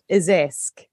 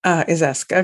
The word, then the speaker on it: Izhevsk: I think it is pronounced Izesk. – Izesk.